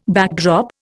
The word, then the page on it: backdrop: Indian